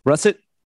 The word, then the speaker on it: russet: He was Russert. I always say that wrong. Russert. Russert potatoes. – Russet. – Russet. Russet. Yeah.